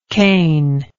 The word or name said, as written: cainitic